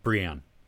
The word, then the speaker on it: Brienne: I liked Jaime, his relationship, Jaime Lannister with Bryn. How do you say her name? Bryn of Tarth? – Yeah, Brienne.